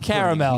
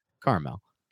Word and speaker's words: caramel: Why is his mouth caramel? Really caramel. – Carmel. – Caramel. – Carmel. – There's another A in there, man. – And I choose not to pronounce it. It sounds way better as Carmel.